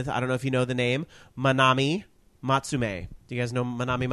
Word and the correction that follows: Matsumae: Matsumae. – Did I pronounce that wrong? Matsumae.